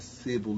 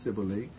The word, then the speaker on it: Cybele: I learned the other day it's not Sybil, that Eli is pronounced Sibylle, who was identified with Greek Artemis.